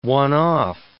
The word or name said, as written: one-off